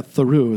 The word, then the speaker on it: Thoreau: And then dad ends up pulling over in Thoreau, New Mexico. Is that how you pronounce it? – Oh, here we go. Yeah, Thoreau is what they say. Thoreau.